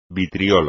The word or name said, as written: vitriolo